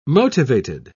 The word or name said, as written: motivated